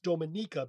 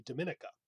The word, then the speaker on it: Dominica: So it's Dominica because we've done this before with Latin. It's a second to last syllable stress. – And the I in Spanish is E, not I. Or yes. – E. – But the problem with it is it's part of the British Commonwealth. So you're tempted to pronounce it as we would in English, which would be Dominica.